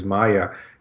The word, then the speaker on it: Maja: His wife's name is Maya. And he corrected my pronunciation because I spelled M-A-G-J-A, and I was pronouncing it Maja. And he's like, no, Maya.